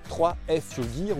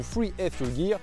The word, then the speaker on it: 3F Fuel Gear: Et aujourd'hui, on va refaire le point sur cette nouvelle marque de tente la 3F Fuel Gear ou Free F Fuel Gear, ça dépend si vous le prononcez à la française ou à l'américaine.